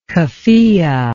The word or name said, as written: keffiyeh